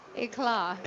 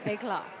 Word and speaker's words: éclat: Éclat, yeah. You have to sound kind of pretentious when you say it. – Yeah, éclat culinary. – The T is silent, éclat.